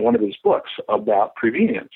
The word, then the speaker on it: prevenience: The word is prevenience. It rhymes with convenient.